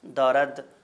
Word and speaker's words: دارد: تو میں عرض کر رہا تھا کہ آنجا جو ہے وہ انجا ہو جاتا ہے، مثلاً دارد کی میں نے مثال دی کہ دارد جو ہے وہ دارے ہو جاتا ہے،